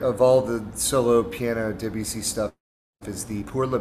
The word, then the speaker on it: Debussy: I mean, I'm not sure I'm pronouncing it exactly correctly either, but Claude Debussy, and it's spelled D-E-B-U-S-S-Y.